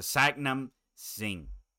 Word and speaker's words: Satnam Singh: Satnam Singh.